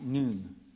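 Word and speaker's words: noon (νῦν): In the Greek, that word now is the word nyn, which is pronounced noon.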